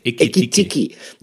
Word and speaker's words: Ekitiki: Das gilt natürlich auch für Hugo Ekitiki. Habe ich ihn richtig ausgesprochen? Ekitiki.